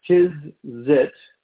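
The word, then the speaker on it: tzitzit: It is called a, and I'm going to spell it for you, it's T-Z-I-T-Z-I-T. T-Z-I-T-Z-I-T. The pronunciation is Tizzit. Tizzit.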